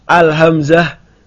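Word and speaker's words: Al-hamzah (الهمزة): Ingat ya, kalau huruf cuma satu bacanya sesuai namanya. Al-hamzah bukan A.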